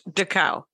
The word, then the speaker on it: Dachau: D-A-C-H-A-U. – Dachau.